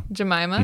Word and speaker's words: Jemima: Jemima.